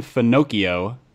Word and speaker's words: Finocchio: Uh, there's a guy from Hillsong named Pastor Nathan Finocchio, rhymes with oh, Pinocchio. Yeah, Finocchio.